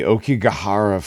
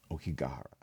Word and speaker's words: Aokigahara: But, dude, the Aokigahara Forest. Aokigahara. I said it wrong the first time.